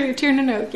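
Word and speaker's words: Tír na nÓg: And the underworld of Tiernanog? – Yes. – Is that how you say it? – Yeah, it is. That's for Tiernanog, yeah.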